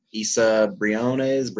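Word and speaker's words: Isa Briones: The quality of acting, Issa Briones, am I saying that right? – Yes.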